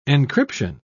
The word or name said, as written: encryption